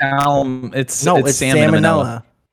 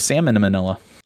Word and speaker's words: salmonella: You say it right? – It's salmonella. I thought it was salmon and manila.